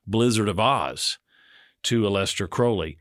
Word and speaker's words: Crowley: Crowley rhymes with unholy. And it's Aleister Crowley, but I may refer to him as Crowley because of Ozzy.